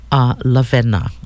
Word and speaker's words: Lavena: But, you know, when I was born, my grandmother gave me a first name, Lavena. And it's spelled L-A-V-E-N-A, of course.